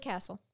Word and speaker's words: Kacel: He said, Judge Matt, probably Castle. I want it to be Castle, even though it's spelled K-A-C-E-L, because I think that's a cool.